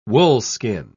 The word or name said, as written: woolskin